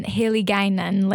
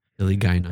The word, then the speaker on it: Hiligaynon: Yeah, I pronounced it, I think, Hiligaynon, because it's spelled with gay in the middle.